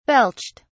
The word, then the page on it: belched: British English